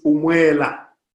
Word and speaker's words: umwela: Wind is umwela. – And how do you spell that? – U-M-W-E-L-A. – Umwela. – Exactly.